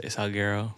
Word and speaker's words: Salguero: I say Salguero.